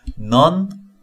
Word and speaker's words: none: None of my friends. 제가 들려라 원서 방송할 때 논이라고 발음하기도 했는데 정확한 발음은 none입니다. 어예요. 어, 짧은 어. 수녀 non 하고 발음이 똑같습니다. nun 수녀 non 그리고 none none none of my friends 내 친구들 중 누구도 뭐뭐가 아닐 것이다.